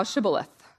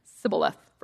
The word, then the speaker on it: Shibboleth: And if he said, 'No,' then they would say to him, 'Say now Shibboleth.' But he said, 'Sibboleth,' for he could not pronounce it correctly.